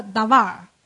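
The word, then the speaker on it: davar (דבר): 这个词，从名词的 Davar。好吧，名词，话语、事情、事物、物质。那这个名词的达瓦尔呢，它也有一个字根的，它是从动词的这个达瓦尔来的。都是念 davar，但是呢这个发音符号有一些微妙的不一样，所以呢它的词性就不一样。这就是动词的说，按秩序安排带领的意思。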